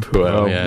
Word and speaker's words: Puel: Puel. – Puel.